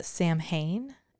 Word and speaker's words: Samhain: But uh, a lot of people believe that the it started with the festival of the Samhain, if I'm saying that correctly, S A M H A I N.